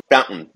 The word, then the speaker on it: Fowntn: If I'm not mistaken, Fowntn.